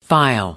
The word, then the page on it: file: American English